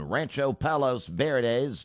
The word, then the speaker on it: Rancho Palos Verdes: at the Trump National Golf Club in Rancho Palos Verdes. Did I pronounce that correctly, mystery woman? – Sounds good to me.